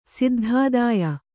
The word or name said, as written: ಸಿದ್ಧಾದಾಯ